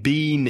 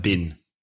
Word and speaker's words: been: But remember that when been is in connected speech in the middle of a sentence, it's usually pronounced been. I've been. B-I-N. I've been.